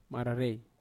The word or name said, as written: mararei